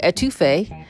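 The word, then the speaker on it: étouffée: We didn't stay in to eat inside, but we ended up getting crawfish with etouffee. That's how it's pronounced, but it's crawfish with etouffee. It's spelled E-T-O-U-F-F-E-E.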